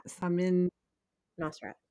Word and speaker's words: Samin Nosrat: Samin Nosrat. – Samin Nosrat.